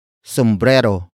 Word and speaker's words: sumbrero: Sombrero. Sombrero. Sombrero is spelled as S-U-M-B-R-E-R-O.